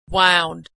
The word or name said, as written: wound